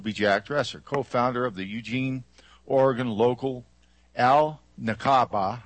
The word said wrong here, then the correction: Al-Nakba: I think I spelled that right. I don't know or said it right. Awareness Project. A group of Al-Nakba. – Okay, thank you.